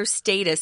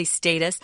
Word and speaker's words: status: How did it acquire or get such a profound status or status? Some people say status. Uh, most Americans say status.